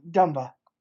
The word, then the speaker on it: Dumba: Dumba.